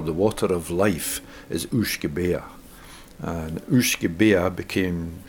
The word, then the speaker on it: uisge: It's U-I-S-G-E, and it's pronounced uisge.